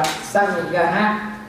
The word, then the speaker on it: Sangha: Sangha ghi nha, tôi đọc là Sangha nha, nha.